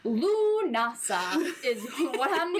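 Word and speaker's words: Lughnasadh: Next is the way it is spelt is not at all how it is pronounced. It is pronounced Lunasa. The spelling is Lughnasad.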